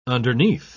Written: underneath

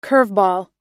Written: curveball

